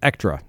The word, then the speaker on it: Ektra: And thank you for pronouncing that Ektra. The KTR was throwing me off.